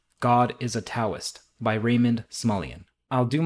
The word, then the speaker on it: Taoist: I also know that the word Taoist is pronounced Taoist, but for whatever reason that feels disingenuous to me saying it, it feels affected on my part.